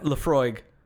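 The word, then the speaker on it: Laphroaig: Lafroig. – Lafroig. – Lafroig. – 10 year. – Tastes like eating wood. – It tastes like eating smoke. – Drinking smoke. What's our tagline for this one? – Lafroig.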